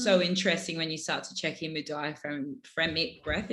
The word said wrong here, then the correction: diaphragmatic: Am I saying that right? – Yeah, diaphragmatic. Yeah.